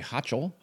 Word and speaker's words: Hochul: New York Governor Kathy Hochul? How do you pronounce her last Hochul.